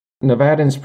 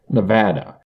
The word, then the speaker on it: Nevada: Nevadans pronounce the second syllable with an A as in strap, Nevada, while some from outside of the state can pronounce it with the A as in palm, Nevada. While the latter pronunciation is closer to the Spanish pronunciation, it is not a pronunciation used by native Nevadans.